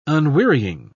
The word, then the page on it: unwearying: British English